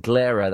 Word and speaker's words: Glera: The album is called Glera. That's spelled G-L-E-R-A.